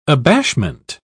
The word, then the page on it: abashment: British English